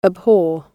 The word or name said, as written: abhor